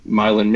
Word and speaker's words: Milan: Right now he's located in Milan, Michigan, or Milan, Michigan. I don't know why they pronounce it that way. It's some kind of bastardization of the word Milan, and you know, in Michigan, you know, just like how they say Montpelier instead of Montpellier in Vermont, and you know, all these types of things.